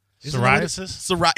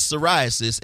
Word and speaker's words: psoriasis: Psoriasis? – Yes. She has psoriasis, and it's taking over her face.